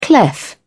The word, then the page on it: clef: British English